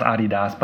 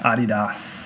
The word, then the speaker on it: Adidas: It's pronounced Adidas, by the way. You Americans, you damn Americans pronouncing shit wrong. Adidas.